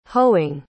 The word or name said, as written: hoeing